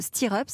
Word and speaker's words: Stir Up: Vous êtes la fondatrice de Stir Up.